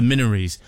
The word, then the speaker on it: Minories: It was at the Minories, as I thought it's pronounced, the Minories. I heard several people referring to it as. So we'll go with that.